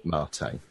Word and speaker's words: Mate: It's a Spanish word. Its origins are in South America. It's a caffeinated tea-like beverage, Mate.